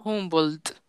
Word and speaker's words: Humboldt: ¿Cómo se pronuncia? – Humboldt.